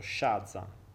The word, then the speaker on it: Shazza: Questa roba qui Ehm, Shazza, quindi senza accento, Shazza, ok?